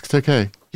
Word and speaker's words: Takei: No, it's Takei. – He used to go on interviews and say it's Takei, like A.